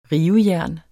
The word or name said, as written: rivejern